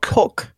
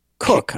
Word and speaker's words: Koch: My name is spelled K-O-C-H, and it's pronounced Cook to rhyme with book. – Cook. – Yeah. – Huh. Cook. I wouldn't have guessed. – K-O-C-H. Yeah. There's no way in the world I'd have got that right. After I learned after marrying into this name that there are actually six different regional pronunciations of K-O-C-H. Cook, to rhyme with book like mine.